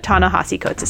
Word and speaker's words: Ta-Nehisi Coates: It's like with an I there, but Ta-Nehisi Coates is how he pronounces his name.